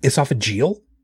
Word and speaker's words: esophageal: Esophageal cancer? – Yeah.